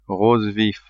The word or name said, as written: rose vif